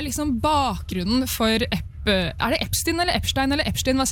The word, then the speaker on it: Epstein: Jeg sier Epstein, og det vist det vi skal si, men det jeg la merke til var jo at du hørte cirka 5-6-7 ulike uttaler av det, vi som satt der, så der er det mange som sier feil, men du skal si Epstein.